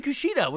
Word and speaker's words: Kushida: And Kushida, which I also nailed.